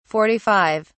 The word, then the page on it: forty five: British English